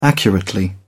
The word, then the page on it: accurately: British English